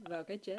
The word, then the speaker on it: Vào cái chết: Is your Vietnamese good? Maybe you could say it. Vào Cà Hai Chết. VAOCAICHET. – Vào cái chết.